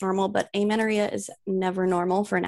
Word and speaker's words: amenorrhea: Amenorrhea. – Amenorrhea, yes.